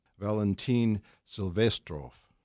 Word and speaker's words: Valentin Silvestrov: And then second on the program is a piece by Valentin Silvestrov.